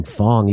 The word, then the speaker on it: Fang: I think you pronounce his last name Fong, even though it's spelled Fang.